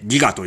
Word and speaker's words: 戯飢: タイトルはその名もギガという、えー、なんだろう、戯れるに飢えると書いてギガと読みます。ね、ジャケットに出てる通りでございます。ということで行ってみましょうギガー俺の毎月の契約、 50 ギガ、 50 ギガ、結局 20 ギガくらいは残って、翌月へパイルダウン。